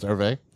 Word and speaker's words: Hervé: I think, I don't know if it's Hervé or Herb. I don't see an accent on the E, so I'm going to say.